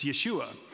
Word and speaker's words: Yeshua: Scholars agree and disagree that this could be Aramaic speaking, this could be Hebrew speaking, but just for clarification, how do you speak Jesus' name in Hebrew? It's Yeshua. How do you say the phrase God's salvation in Hebrew?